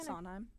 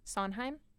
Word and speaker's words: Sondheim: Sondheim. – Sondheim.